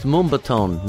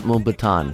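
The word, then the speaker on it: Mumbatón: it's pronounced Mumbaton, not Mumbaton, because he corrected me once before. – Mumbaton. Okay. – Mumbaton, yeah, like reggaeton. Mumbaton.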